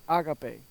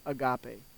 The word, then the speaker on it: agape: And those four distinct words for love in the Greek are phileo, eros, storge, and agape, which we usually pronounce as agape, but it's agape. Agape.